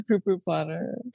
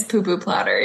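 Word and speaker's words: pu pu platter: Is it the poo-poo platter? – It definitely is a poo-poo platter. – It's poo-poo platter, yes.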